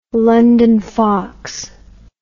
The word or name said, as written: london fox